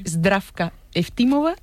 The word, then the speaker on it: Zdravka Eftimova: A ver, Georgi Gospodinov, sí. – Zdravka Eftimova y Vera Mutavcheva. – Ahora sí, ahora sí.